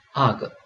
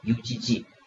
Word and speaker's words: UGG: 这里要注意一下这个 UGG U G G，我们国内呢通常都直接读成这个 U G G，那呢这个外国人呢是把它读成 UGG。